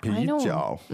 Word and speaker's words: 啤酒: Now, the tones there are? – Second and third. – Good, second and third. Let's hear that again. – 啤酒啤酒. – Stop saying that. You're making me thirsty. 啤酒.